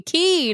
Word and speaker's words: Key: they pronounce it correctly. Key, not K. – All this rhyming nonsense. Like, stop it. – I know. Stop with the rhyming nonsense. Pronounce it like the Bahamians pronounce it.